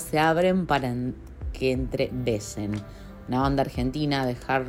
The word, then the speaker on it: Wesen: Soy Néstor Fernández, cantante y guitarrista de la banda de hard rock industrial Besen, que se escribe W-E-S-E-N.